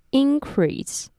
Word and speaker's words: increase: 这个字呢，也可以当成名词使用，但是要注意重音和动词的时候不同哦。念作 increase。